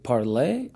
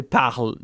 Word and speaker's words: parle: Is it Parler? – I don't know. To me, I'm a French, so I say Parle. The real pronunciation is Parle, but yeah, I guess English people might say Parler, I guess.